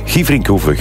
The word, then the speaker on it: Gieverinkhoeve: Benoit Dorné stuurt, hey Björn, de rally is in Gieverinkhoeve, zo spreek je het uit. Gieverinkhoeve, groetjes, de papa van Jules.